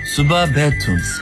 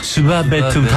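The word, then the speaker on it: 수바 베툼 (සුබ පැතුම්): 수바 베툼. – 그렇죠. 수박을 뱉은 게 아니고요. 수바 베툼.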